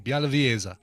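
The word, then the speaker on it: Białowieża: Bialowieza. – Bialowieza. It's a Polish name, so might be a V. W might be pronounced like a V. Bialowieza. – There you go.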